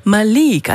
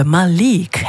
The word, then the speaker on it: Malik: Malik, also Betonung liegt auf der zweiten Silbe, Malik Harris.